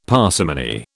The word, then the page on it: parsimony: British English